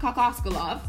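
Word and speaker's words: Kokoškov: Toronto then goes on a 9-0 run to force a timeout from Igor Kokososkalov. Igor Kokoskalov, there you go, who, much like Nick Nurse, is in his first season as a head coach.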